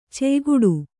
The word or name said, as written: ಛಾಯೆಗೆಡು